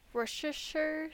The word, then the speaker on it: Worcestershire: And then there's some, I don't know how to say this word. Worcestershire sauce? – Yes, exactly. Nailed it.